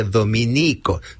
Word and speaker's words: Dominico: Si se alude a los religiosos de la Orden de Santo Domingo, se escribe Dominico, sin tilde en la I.